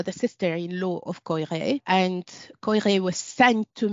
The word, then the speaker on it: Koyré: his mentor and friend Koyré. Uh, I think some people pronounce it Poiré.